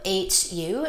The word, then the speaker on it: HU: My favorite word to use is HU, H-U, it's pronounced HU.